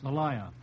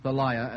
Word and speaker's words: Thalia: I'm not sure whether that's pronounced. – Thalia. – Thalia, or Thalia. You boys can fight it out outside.